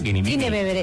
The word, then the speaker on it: Guinevere: Guinevere.